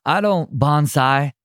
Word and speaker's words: bonsai: I don't bonsai. How do you say it exactly? Bonsai? – Bonsai. – Bonsai. Okay, I knew I was saying it wrong.